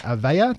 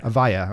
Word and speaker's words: Avaya: You formerly worked for Cisco and Avaya. Did I say that right? – Avaya, I'm sorry.